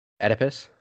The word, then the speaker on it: Oedipus: Oedipus? – Yes, thank you. I can't have another instance of saying something wrong right now. It's already been, like, a hard week. – Oedipus gives me PTSD from the second or third year Sophia and I had this Greek archaeology class.